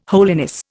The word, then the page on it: holiness: Indian